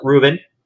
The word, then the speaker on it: Rubin: And they call it Reuben. It's not the sandwich, although I wish it was because I'm hungry now. I love Rubens. And it's spelled differently, too. It's R-U-B-I-N.